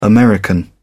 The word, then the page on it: american: British English